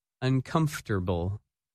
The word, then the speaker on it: uncomfortable: One of the new words in this lesson can cause problems for English learners. That word is uncomfortable, and its opposite, comfortable. When you say this word, you do not pronounce some of the letters that you see in the written word.